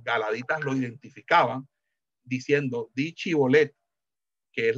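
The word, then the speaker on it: chibolet (שיבולת): Entonces, si no podían, no podían decir chibolet, sino cibolet, sabían que era, de, eh, era eh, una pronunciación propia de los, de los efraimitas. Eh, esa pronunciación de, de, la chin por la sin era,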